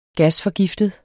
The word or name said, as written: gasforgiftet